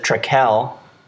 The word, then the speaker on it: Trekell: Tony pronounces it Treckel, but I'm going with Treckle. Let us know what you think. Really explore the space. T R E K E L L, one K, two L's.